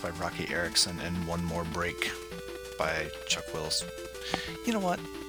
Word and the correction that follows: Chuck Willis: I think I said that wrong. I can never remember. Yeah, Chuck Willis, not Chuck Wills.